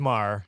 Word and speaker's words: Maher: It's Maher. – Maher. – You don't have to pronounce the yeah. – H.